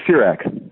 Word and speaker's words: Sirach: And, of course, we have to get to Sirach. Is that how that's pronounced? – Yes, yeah, Sirach, but yes.